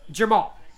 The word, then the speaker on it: Jermall: Jermall. That's what I said, right? I said Jamal or Jermall. – Yeah, I was just making sure you had it pronounced correctly. Jermall.